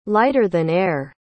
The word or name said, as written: lighter-than-air